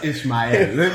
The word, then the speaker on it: Ishmael: It's Ishmael. – You said my name wrong.